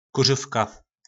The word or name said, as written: kurovka